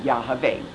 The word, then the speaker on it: Yahweh: In the Hebrew, Zechariah's name means remembered of Yahweh, YHVH, the correct pronunciation of the sacred name of the Lord, Yahweh.